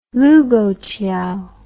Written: Lugouqiao

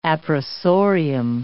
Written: appressorium

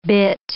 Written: bit

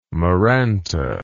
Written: maranta

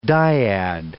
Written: dyad

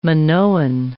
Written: Minoan

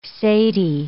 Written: cedi

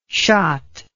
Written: shott

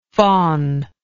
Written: faunlike

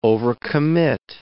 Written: overcommit

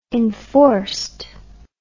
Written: enforced

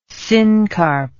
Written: syncarp

